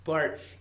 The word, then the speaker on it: Barth: So one thing about Karl Barth, and it's spelled Barth, but right pronunciation is Barth.